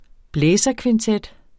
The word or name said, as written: blæserkvintet